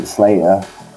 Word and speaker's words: Sl8r: Yeah, I pronounce it Sl8r.